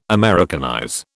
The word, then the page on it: Americanize: British English